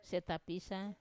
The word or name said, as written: setapisa